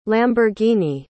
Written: lamborghini

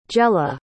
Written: gella

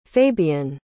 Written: fabyan

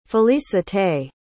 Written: felicite

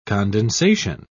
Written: condensation

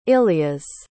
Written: illias

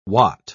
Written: wot

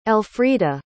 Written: elfrida

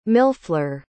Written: millefleur

